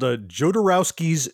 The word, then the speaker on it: Jodorowsky: And it's called Jodorowsky's Dune.